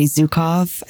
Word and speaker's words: Zukav: It's about emotional awareness by Gary Zukav.